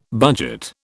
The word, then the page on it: budget: British English